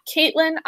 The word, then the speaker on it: Caitlin: I am at Caitlin IR Foster, and Caitlin is spelled C-A-I-T-L-I-N.